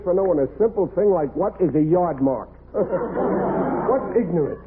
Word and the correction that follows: aardvark: You can't even pronounce it. It's aardvark.